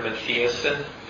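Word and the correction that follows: Matthiessen: guy who wrote... Matheson's how you say it.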